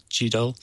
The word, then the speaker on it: GDAL: So one of the key ones is called GDAL, or G-D-A-L, GDAL. The author pronounces it GDAL.